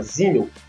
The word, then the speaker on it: Simmel: Se escreve com S, mas se fala Simmel.